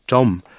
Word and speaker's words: 점: 점. And know when to pronounce it with the double J.